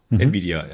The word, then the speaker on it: Nvidia: Wie wird das ausgesprochen? Nvidia? – Nvidia, ja.